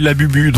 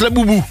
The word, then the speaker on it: Labubu: Bah moi, je lisais Labubu, donc voilà, je suis pas dans le coup. – Ah non, ça se prononce Laboubou.